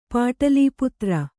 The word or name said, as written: ಪಾಟಲೀಪುತ್ರ